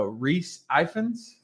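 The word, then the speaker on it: Rhys Ifans: Oh, Reese Ifans.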